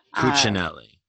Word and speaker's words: Cuccinelli: So can you tell me how to pronounce Ken's last name other than twat? Because I don't actually know like how to say it. – Ken Douchebaganelli. – Douchebaganelli. Cuccinelli. – Cuccinelli. – There we go.